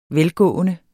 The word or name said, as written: velgående